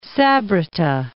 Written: Sabrata

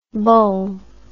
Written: boules

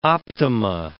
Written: optima

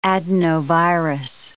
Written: adenovirus

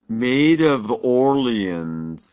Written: Maid of Orleans

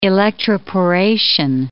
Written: electroporation

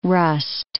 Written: rust

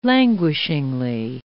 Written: languishingly

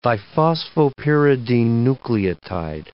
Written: diphosphopyridine nucleotide

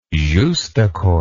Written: justaucorps

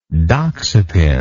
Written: doxepin

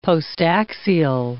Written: postaxial

